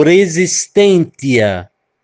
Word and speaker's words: resistentia: A palavra resistência vem do latim e ela é pronunciada como resistentia, ou seja, suportar ou reagir.